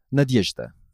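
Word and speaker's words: Nadyezhda (Надежда): And you say it's 3 syllables. Nad. – Nad. – Yezh. – Yezh. – Da. – Da. – Nadyezhda. – Nadyezhda. – Perfect.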